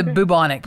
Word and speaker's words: bubonic: Bluebonnet plague was. – I think it's the bubonic plague. – Bubonic. – Bubonic. – Is that a bionic situation? No, it's bubonic.